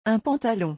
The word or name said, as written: un pantalon